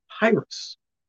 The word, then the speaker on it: Pyrus: This year's American James Dyson Award winner, Gabe Tavas, takes on deforestation with his invention, Pyrus. Not Pyrus, not Papyrus, Pyrus. So yeah, like spelled like papyrus, but without the pot at the beginning.